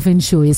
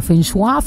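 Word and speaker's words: feng shui: Oye, de hecho creo que no se pronuncia fenshui, es como fenshua, fenshu, algo así.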